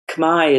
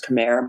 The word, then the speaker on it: Khmer: And the way the Khmer is the proper pronunciation, everybody says Khmer, but it's actually Khmer.